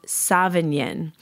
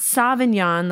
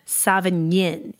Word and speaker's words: Savagnin: And then the other white grape varietal that is predominantly grown is called Sauvignon. Not Sauvignon like Sauvignon Blanc, but Sauvignon. You kind of like do a little nying at the end of it. It's spelled Savagnin. That's where the nyin comes from.